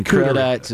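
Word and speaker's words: crudité: Yeah, we will have state-funded charcuteries and crudites available. – Are you talking about charcuterie? – And crudites. – And how do you say that one? Crudite? – Crudite.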